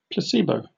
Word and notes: placebo: British English

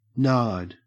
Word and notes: nard: Australian English

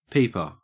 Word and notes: peeper: Australian English

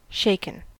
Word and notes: shaken: American English

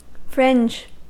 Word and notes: fringe: American English

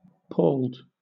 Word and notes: palled: British English

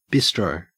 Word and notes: bistro: Australian English

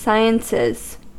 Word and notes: sciences: American English

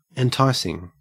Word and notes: enticing: Australian English

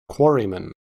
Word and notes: quarryman: American English

